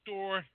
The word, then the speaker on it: storge: And I'm going to slip down to the next one, which is pronounced storge. Storge.